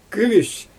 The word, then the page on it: kümüs: Xyzyl